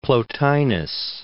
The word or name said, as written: Plotinus